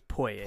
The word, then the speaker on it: Poyais: I think it's Poye. – Poye? – Yeah. I think the S is silent. – P-O-Y-A-I-S.